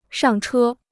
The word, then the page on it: 上车: shàng chē